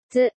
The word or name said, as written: つ